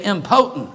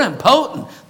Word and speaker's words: impotent: Whether you're short or tall or rich or poor, you're impotent. That is not the pronunciation, and that's not what that means. The word is impotent. They were impotent folk.